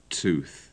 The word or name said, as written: tooth